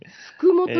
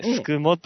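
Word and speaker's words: 宿毛: すくもというとこなんですよね。 – はい、はい、宿るに家と書いて、すくもと呼ぶところなんですけども、はい。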